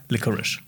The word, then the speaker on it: Licorice: Ja, ich habe es nämlich erst falsch gesagt beim Dreh. – Mhm. Dann hat mir der Kameramann gesagt, so, ich glaube, das heißt Licorice. Und habe ich nochmal nachgeguckt und das heißt anscheinend Licorice.